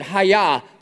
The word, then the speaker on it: Yahweh: And so the word, the name Yahweh, it's supposed to sound like a breath, like to inhale and exhale, Yahweh, all right? Yahweh. Now, my seminary professors would pronounce it Haya, but all I could ever think of is like a little Judy chop, you know what I mean?